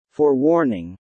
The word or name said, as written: forewarning